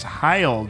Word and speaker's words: Heil: I still like how your microphone stands are Hail. – It's Heil, dude. Hail is spelled like with an A, dude.